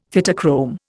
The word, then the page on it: Phytochrome: Indian